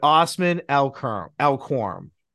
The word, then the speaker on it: Osman Elkouram: But without further ado, if I say this wrong, because we practice this like 10 times before the year, but Osman Elkouram.